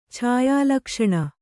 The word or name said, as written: ಛಾಯಾಲಕ್ಷಣ